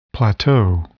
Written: plateau

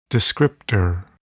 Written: descriptor

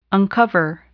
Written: uncover

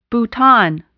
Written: bouton

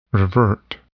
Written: revert

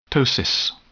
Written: ptosis

